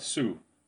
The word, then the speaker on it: Sioux: Uttalas Sioux, men skrivs Sioux. Denna märkliga franska stavning med bokstäver på slutet av orden som inte uttalas.